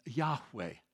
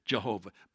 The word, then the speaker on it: Yahweh: Sometimes you've heard God's name pronounced Jehovah. You've heard that before? Well, there's no J or V in the Hebrew alphabet, so they could not have pronounced it Jehovah.